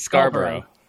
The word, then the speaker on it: Scarborough: No, that's exactly how I pronounce it. It's Scarborough. Scarborough. – Yeah, Scarborough. So we say Scarborough, Scarborough, Scarborough, and up there.